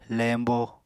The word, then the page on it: leñbo: Apatani